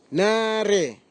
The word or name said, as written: nare